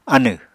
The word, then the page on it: anẁ: Apatani